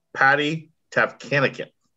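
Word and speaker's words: Tavatanakit: Patty Tavkanikin. – That was pretty solid. I didn't think you were actually going to be able to say her name correctly, so I'd give you props for that.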